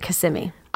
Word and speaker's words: Kissimmee: No, and I'll tell you how I know that I'm not pronouncing it wrong. It's because I looked up videos produced by the city of Kissimmee, and their host pronounces it Kissimmee.